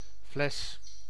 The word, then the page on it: fles: /flɛs/